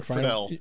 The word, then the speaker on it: Friedle: I think is how you pronounce his last name. – Yep. Will Friedel. – Friedel. – Friedel. She says it the right way.